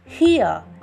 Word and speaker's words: hir: Instead of his or her these days, to use a non-gender specific pronoun, often spelled as H-I-R in smaller case, that is pronounced as here, is used.